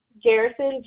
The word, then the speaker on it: Garrison: Garrison, G-A-R-R-I-S-O-N.